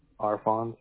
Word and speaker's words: Arfons: Arfons. A-R-F-O-N-S. – A-R-F-O-N-S? – Correct. – Okay. Arfons, you spell it B-R-I-A-N? – Correct.